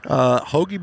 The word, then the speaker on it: Hoggie: Hoagie Baits. – Spell that for him. H-O-G-G-I-E.